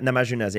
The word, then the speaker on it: Namajunas: Rose Namajunas? How do you say her? – Rose Namajunas, yeah.